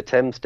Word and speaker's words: Thames: Thames. – The Thames Delta. – Yeah. He's always like, I'm writing from the Thames Delta.